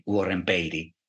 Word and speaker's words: Warren Beatty: probabilmente non vi diranno mai Warren Beatty, ci dice lui che si pronuncia così.